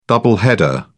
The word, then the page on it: double header: British English